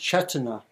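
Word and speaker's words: cetanā: And again, another word that is used here more in the tradition is, I think it would be pronounced chetana. It's spelled C-E-T-A-T-A. C-E-T-A-T-A, usually translated as intention, sometimes as volition or will.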